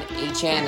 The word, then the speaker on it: Achane: Devon Achane, as he wants to be pronounced by.